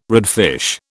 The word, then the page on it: redfish: British English